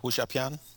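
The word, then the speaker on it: Wuxia Pian (武侠片): Is that the right pronunciation? You that uh, you're studying Chinese a little bit more than I am, so. – Uh, Wuxia Wuxia Pian. – Yeah. There we go.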